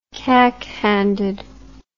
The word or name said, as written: cack-handed